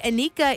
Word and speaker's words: Anika: R-E-L-I-Q-A is how you spell it. But hey, Anika is my name. A-N-I-K-A is how you spell that.